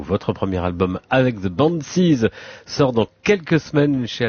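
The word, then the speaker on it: Banxhies: Alors le X est écrit en grand car il barre un S qui nous a été cher payé puisqu'on s'appelait The Banshees, hérité d'une figure de la mythologie gaélique. Je vais pas trop m'étaler là-dessus, mais on a dû changer de nom. Ça se prononce Banshees ou Banshees, c'est vraiment comme on veut.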